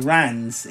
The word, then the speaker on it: Reims: The music is all done by a guy called Jan van der Kruisen, who is from Reims, or Rans in France, as it's actually pronounced.